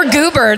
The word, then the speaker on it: Gober: Oh, Gober. – Or Goober. There's not two O's. Goober's better, but it's spelled Gober.